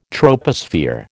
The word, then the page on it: troposphere: American English